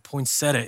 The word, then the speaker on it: poinsettia: Do you say poinsettia or poinsettia? Poinsettia is the Menlo Church approved pronunciation.